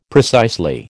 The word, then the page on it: precisely: American English